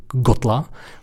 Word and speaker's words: Kgotla: ale jmenuje se zhruba jako Gotla, píše se to Kgotla, ale nedokážu to vyslovit, protože v té výslovnosti, v tom jazyce secvaná je taková mlaskavka.